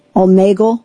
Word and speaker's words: Omegle: Omegle? – Omegle.